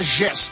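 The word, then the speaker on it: Jess: Como se escreve Gess? É Pedro Gess, né? Gess é J-E-S-S. J-E-S-S. É assim que se pronuncia Gess, tá bom?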